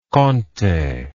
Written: conte

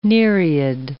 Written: nereid

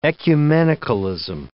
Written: ecumenicalism

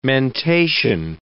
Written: mentation

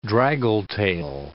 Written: draggle-tail

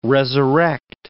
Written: resurrect

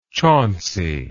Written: chauncey

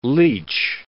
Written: leech

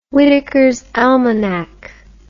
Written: whitakers almanack

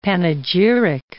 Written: panegyric

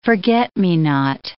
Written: forget-me-not

